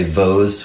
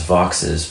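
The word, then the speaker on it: Vaux: I don't know much about his background, but it's some people say Vo's swift, but it's actually pronounced Vox's because that's the way he pronounced his name.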